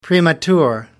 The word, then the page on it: premature: British English